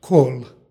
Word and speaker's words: kol: stihu u prvoj reči pročitali smo kol. Ovo je prilog i izgovara se sa L na kraju reči, dakle kol.